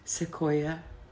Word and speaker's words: Sequoia: Sequoia. – Sequoia. – Sequoia. – Pronounced Sequoia or? – Sequoia.